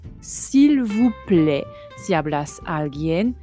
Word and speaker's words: vous: S'il vous plaît. S'il vous plaît. Vous, cuidado. Se pronuncia soplando aire entre los dientes superiores y el labio inferior.